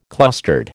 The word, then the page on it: clustered: American English